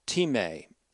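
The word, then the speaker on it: time (τιμή): The transliteration of that looks like our word time, but it's really T-I-M-A-T-A-E, would be pronounced time in the Greek.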